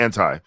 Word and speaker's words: Anti: It's Anti. A friend sent me a text. It was like, I'm having a hot debate. Is it Anti or Anti?